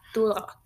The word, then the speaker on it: Duloc: And Donkey just insists on taking along to show Shrek the way to Duloc. Is that how you say it? – Yeah, Duloc.